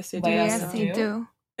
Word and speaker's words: Seydoux: Lea Seydoux.